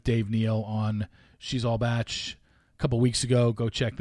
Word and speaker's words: Bach: And that's why I promote people like Dave Neal and Game of Roses and She's All Batch or She All Bach. That is hilarious that Ben thought that's how you pronounced it. I get it. B-A-C-H.